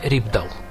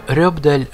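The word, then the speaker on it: Rypdal: И трудно привести пример музыканта, который уже после этого так свободно трансформировал бы гитарный звук, наполняя его неповторимыми нюансами и интонациями, как Терье Рибдал. Будем так произносить его фамилию, хотя сами норвежцы, соотечественники музыканта, умудряются выдать нечто среднее между Рёбдаль, Рибдаль и Рюбдаль.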